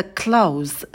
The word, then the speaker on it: close: На «к» добавляем придыхание, «к», «л» идут вместе, поэтому, когда произносим звук «к», то уже язык ставим на альвеолы, готовимся к звуку «л». Close. И потом дифтонг «эу», такой передний, «эу», «эу», и в конце звук «з» звонкий, не оглушайте.